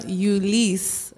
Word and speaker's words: Ulysse: So the Americans, we know how to say it profoundly the Haitian way. Mm-hmm. It's Theodora Ulysse Joseph.